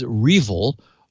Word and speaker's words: REvil: It's also known as Revil, R-E-V-I-L, as in R evil, Revil.